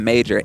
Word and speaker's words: Maejor: On anything, just type in Maejor, M-A-E-J-O-R. That's in social media, that's in YouTube, anything, M-A-E-J-O-R.